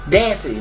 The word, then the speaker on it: Dancy: Dancy, like it rhymes with fancy, but... Dance with a Y on the end.